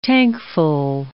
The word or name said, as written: tankful